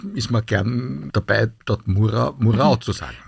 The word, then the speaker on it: Murau: Das ist ja wie Murau und Murau, also es heißt Murau, nicht?